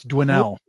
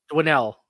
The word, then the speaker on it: Dwinnell: I am going former WWF referee, David Dwindell. – Yeah, it's Dwinell. – Dwinell. Yep. – How do you spell that? – D-W-I-N-N-E-L-L.